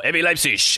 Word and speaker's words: RB Leipzig: And yeah, when you saw that second goal go in, which was just before half-time, another indication this wasn't going to be RB Leipzig's, or RB Leipzig, as the German pronunciation goes.